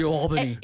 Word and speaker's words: UAlbany: U Albany. – It's U Albany. I got that now.